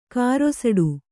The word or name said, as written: ಕಾರೊಸಡು